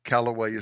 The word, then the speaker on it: Calloway: Calloway is spelled C-A-L-L-O-W-A-Y.